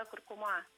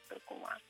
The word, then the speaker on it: куркума: Но интересно, я бы даже сказала загадочно и парадоксально, что куркума-куркума прошли ровно обратный путь. То есть ударение куркума, оно такое гармоничное для русского языка, потому что ударение стоит в середине корня. Но русским людям, носителям русского языка, захотелось, чтобы она была куркума.